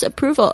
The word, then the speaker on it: approval: I find the balance between needing others' approval. Approval 就是肯定， A-P-P-R-O-V-A-L Approval 这个字有一点难念，因为它有 R 还有 V 的发音，都是我们中文没有的声音。 Approve 那个 R 的声音卷舌 R 的声音 Approval，然后 V 有轻轻的咬住下嘴唇，然后 AL 的声音是那个后舌音，不是后舌音，是那个抬舌头的那个音哦，所以是 Approval 这边一直在喷麦克风，我希望大家不要就是太介意 Approval OK.